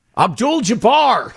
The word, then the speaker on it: Abdul-Jabbar: I know him, and I did mispronounce his name. – What did the all crowd right. say there? – Abdul-Jabbar! – Is that what they said? – Yes.